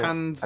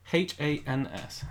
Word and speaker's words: Hans: Hans, I believe it is. – H A N S.